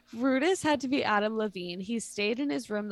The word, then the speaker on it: Levine: Is that how you say his name? Levine. Okay. I couldn't remember.